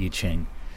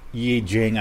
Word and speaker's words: I Ching: I space C-H-I-N-G. Some people pronounce it differently. I'm just going to call it I Ching. Yi Jing, I guess, is another way to say it.